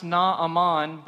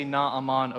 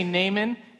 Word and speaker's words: Naaman: It's technically pronounced Naaman, but I sound pretentious when I say it, and I don't want to say Naaman over and over again. So I'm just going to say Naaman, and we're all just going to pretend like that's how it's pronounced.